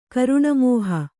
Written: ಕರುಣಮೋಹ

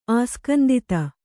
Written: ಆಸ್ಕಂದಿತ

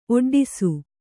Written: ಒಡ್ಡೈಸು

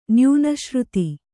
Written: ನ್ಯೂನಶ್ರುತಿ

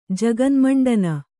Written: ಜಗನ್ಮಂಡನ

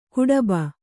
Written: ಗಡುಬ

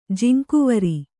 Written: ಜಿಂಕುವರಿ

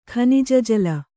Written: ಖನಿಜಜಲ

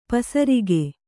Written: ಪಸರಿಕೆ

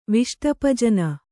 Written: ವಿಷ್ಟಪಜನ

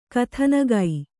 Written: ಕಥಾನಕ

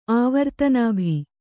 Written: ಆವರ್ತನಾಭಿ

